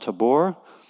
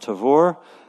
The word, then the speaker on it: Tabor: There's a mountain called Mount Tabor, pronounced usually Mount Tavor.